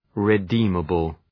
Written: redeemable

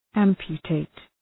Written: amputate

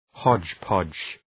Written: hodgepodge

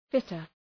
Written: fitter

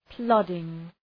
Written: plodding

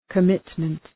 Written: commitment

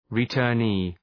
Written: returnee

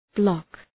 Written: bloc

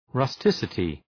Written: rusticity

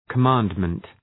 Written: commandment

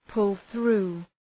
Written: pull through

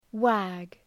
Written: wag

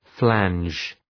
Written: flange

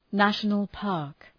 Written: national-park

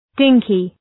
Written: dinky

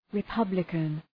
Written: republican

